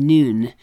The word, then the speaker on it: Nun: Kelly Ogden note, Joshua was the son of a man named Nun, pronounced like our English word noon.